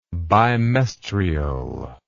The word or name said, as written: bimestrial